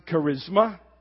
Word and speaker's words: charisma: The word gift here is the word charisma. We pronounce it charisma.